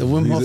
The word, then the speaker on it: Wim Hof: Like, you're fitness trainer, fight trainer, event promoter, corporate group training, public speaking, and I don't know if I'm saying it right, Wim Hof method. – Yeah, the Wim Hof method.